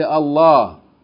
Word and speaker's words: Allah: Or in this case, you pronounce it with tafkheem. You say, Allah.